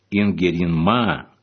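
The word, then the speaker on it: Inkerinmaa: Название Ингерманландия по финскому произношению Ингеринмаа в переводе означает «земля Ингигерды» и ведет свое происхождение, по всей вероятности, именно от имени супруга Ярослава.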